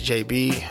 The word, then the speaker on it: Jabee: Everything is at my name is JB, and JB is spelled JABEE. So just search JB.